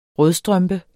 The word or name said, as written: rødstrømpe